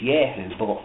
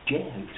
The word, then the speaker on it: Jehu: I was like not 100% sure how to pronounce some of these names, because of course in Hebrew the J is not pronounced, it's Yeh. So strictly speaking it's Jehu, but as it reads in our Bible, Jehu.